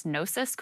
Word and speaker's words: Gnosis: They did this report that found over 6 days last August, one crypto wallet amassed a stake of $360,000 worth of, how do you say this, Sagar? G-N-O-S-I-S, Gnosis coins? – Yeah, that's Gnosis.